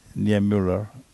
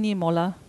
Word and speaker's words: Niemöller: Sì, c'è un'altra citazione sulla radio, vedete lì in verde, la citazione di Martin Niemöller. – Lo pronunciano Niemöller, ma si scrive qua all'italianizzato Niemöller, in tedesco dico Niemöller.